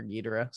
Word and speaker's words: Ghidorah: I mean, what's really special about it is the first time we get to see Ghidorah, and Ghidorah is, let's face it, Godzilla's like big bad. That's his villain. Like the Joker to Batman, it's Godzilla to King Ghidorah, right? Or Ghidorah, sorry. There's like 30 different ways you could pronounce his name.